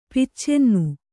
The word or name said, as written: ಪಿಚ್ಚೆನ್ನು